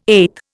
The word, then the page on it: eth: Indian